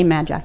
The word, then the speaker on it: MAGI: Since her, you say Magi? Is that how you say it? – You can say Magi. – Magi? – Magi. – All right, Magi.